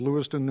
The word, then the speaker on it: Lewiston: Is it Lewiston, New York? – Yes, it is.